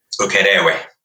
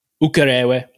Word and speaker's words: Ukerewe: Did I pronounce it correct? – Ukerewe. – Ukerewe.